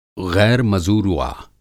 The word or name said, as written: ग़ैर मज़ुरूआ